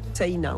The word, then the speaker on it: Sena: Ça s'écrit un peu comme la Seine, mais... – Voilà, avec un A à la fin, Sena, on prononce le I aussi. – Comme si on avait un tréma au-dessus. Ouais.